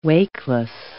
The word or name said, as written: wakeless